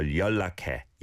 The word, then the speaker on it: 연락해: Again, yeah, trying to overpronounce the 연락, 연락. It's not like that. 연락, 연락. The neon blends into the leal of lak and basically is a leal. So 연락해, 연락해, 연락해요 to be more polite.